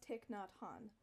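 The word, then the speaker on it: Thich Nhat Hanh: So it's, it's pronounced Tik Nhat Han.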